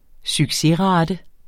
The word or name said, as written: succesrate